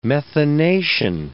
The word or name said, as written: methanation